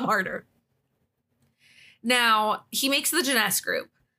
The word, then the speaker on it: Jness: The first one was in 2006, and it's called Jness.